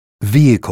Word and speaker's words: vehicle: Je ne prononce pas le H. Mot à apprendre par cœur. – Honor. Air. Vehicle.